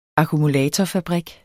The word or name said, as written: akkumulatorfabrik